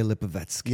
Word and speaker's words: Lipovetsky: We got Gary and Valeria Lipovetsky.